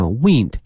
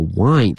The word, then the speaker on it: wind: wind 它这个拼写啊像风一样哈 wind 但是呢它的发音是这个 wind 因为它跟风没